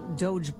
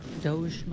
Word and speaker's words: DOGE: In addition to, you know, Doge blowing up the... Doge, am I saying it right? – You're saying it right.